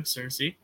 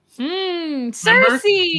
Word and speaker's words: Cersei: Cersei. Remember? Yes. – The two guys from Game of Thrones. Yeah. – Wow, that spelling, I would have never assumed that Cersei was the way you pronounce that.